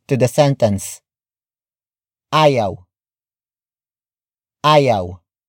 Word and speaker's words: ayaw: Ayaw matulog ni Chris. Ayaw matulog ni Chris. The first word is ayaw. Ayaw. Ayaw. Ayaw is spelled as A-Y-A-W.